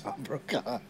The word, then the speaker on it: Tom Brokaw: What happened to Tom Brokaw? – I'm sorry.